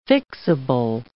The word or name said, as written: fixable